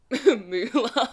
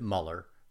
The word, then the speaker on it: Mueller: It's German. So, my family pronounces it Muller, but a lot of people pronounce it Mueller as well. Either way, I answer to anything. Doesn't really matter.